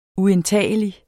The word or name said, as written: uindtagelig